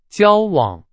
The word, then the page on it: 交往: Chinese